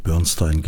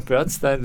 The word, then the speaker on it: Bernstein: Das verwirrt mich immer, wie die Amerikaner diese ursprünglich deutschen Namen, die auf Stein enden oder Stein enden, dann aussprechen, aber sagen wir Bernstein, whatever. – Also alle, die ich kenne, die ihm persönlich begegnet sind, haben alle Bernstein gesagt.